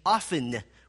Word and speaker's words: often: O-F-T-E-N is often? Who believes that's the correct way? Who believes it's correct to say often with a silent T? Okay, what a way to split the church. The correct way is often with a silent T.